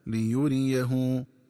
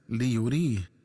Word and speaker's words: ليريه: ينبغي التنبه للنطق الصحيح للفظ ليريه، فيقرأ بفتح الياء الثانية وضم الهاء هكذا ليريه، ومن الخطأ قراءته بتسكين الياء وكسر الهاء هكذا ليريه.